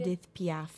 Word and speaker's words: Edith Piaf: The original is by Edith Piaf. Is that how you say it? – Edith Piaf.